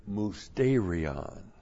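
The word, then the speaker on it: musterion: Point three, the indwelling of Jesus Christ relates to the word mousterion. Mousterion. It's the Greek word M-U-S-T, long E-R-I-O-N.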